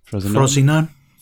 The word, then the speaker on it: Frosinone: Is that how you pronounce that? Frosinone? – Frosinone.